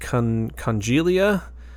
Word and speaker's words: Congelia: And then they released Congelia, that's how I'm pronouncing it. It's C-O-N-G-E-L-I-A.